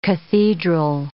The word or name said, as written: cathedral